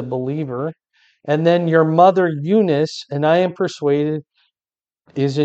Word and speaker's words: Eunice: Eunice is Timothy's mom, Eunice, is how you pronounce it.